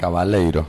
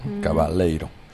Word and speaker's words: Cavaleiro: No sé si lo digo bien el apellido. – Cavaleiro, suena brasilero. Cavaleiro.